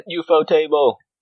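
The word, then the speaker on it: ufotable: And that's why I found out it was done by UFO Table. Is that how you say it? – UFO Table.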